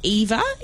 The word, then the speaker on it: Ewa: Then we've also had, I think it's pronounced Eva, E-W-A.